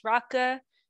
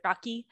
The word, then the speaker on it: rakı: Okay, so I'm going to talk a little bit about rakı, which is the national drink of Turkey, spelled R-A-K-I, pronounced rakı. So, you know, I've heard a lot of people pronounce it rakı. – Well, it's because it has the weird I without the dot.